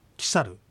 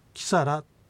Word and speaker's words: キサル: まず概念形耳、キサル、キサル。 – 今キサルと発音しましたが、この R で終わってる音はキサラと発音してもキサルと発音しても結構です。